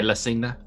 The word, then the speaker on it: La Cena: The other one is, and this is my pronunciation is wrong, La Cena, like the dinner. – Yeah, La Cena, which is like this takes place during a dinner.